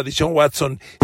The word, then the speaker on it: Deshaun Watson: Yo sí espero un ascenso tremendo de Cleveland con Deshaun Watson. Y sí, lo dije bien, ¿eh? Un ascenso tremendo, así, bárbaro. Miren si Cleveland le puso el contrato que le puso a Dishon Watson 100% garantizado, es porque saben el talento que tienen.